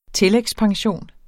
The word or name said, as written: tillægspension